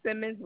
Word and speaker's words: Simmons: Melissa Simmons, which is spelled M-E-L-I-S-S-A. Simmons, S-I-M-M-O-N-S.